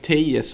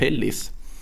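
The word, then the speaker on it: Alex Telles: Vet du att Alex Tejes, som man tror att han har uttalat, han är ju portugis. Så tydligen så uttalas Alex mm. Tellis. – Tellis? Ja, vi pratade om det här i någon deadline i sändning någon gång där jag insåg att det är Alex Tellis.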